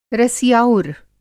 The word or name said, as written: रसिआउर